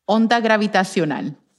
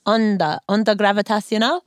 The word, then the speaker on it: onda gravitacional: How do you say that in Spanish, by the way, gravitational wave? – Onda gravitacional. – Onda gravitacional? – Very good. Perfecto.